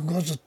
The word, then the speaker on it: 牛頭: ちょっと五頭って僕はねなまってるわけじゃないんだけど、これが正しい発音なので、五頭ってのは牛さん、つまり牛ですよね。牛の頭って書いて牛頭、五頭、五頭天皇というふうに呼ぶわけですけれども、この五頭天皇をね運び込んできた恐ろしい神さんらしいです。